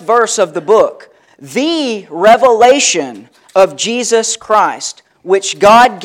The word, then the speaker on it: Revelation: Maybe you've pronounced the book Revelations with an S on the end. That's not what this book is.